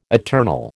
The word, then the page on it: eternal: American English